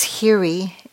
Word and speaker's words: Hiri: In the ancient language of Pali that the Buddha's teachings were translated from, these are known as Hiri, and it's spelled H-I-R-I, and Ottappa, O-T-T-A-P-P-A, for those of you who are interested, but you don't have to remember that.